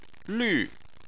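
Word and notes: lv: tone 4

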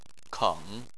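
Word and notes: keng: tone 3

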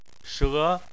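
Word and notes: she: tone 2

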